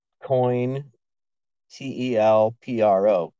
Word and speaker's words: COINTELPRO: Which is spelled COIN, T-E-L-P-R-O, COINTELPRO, Counter Intelligence Program.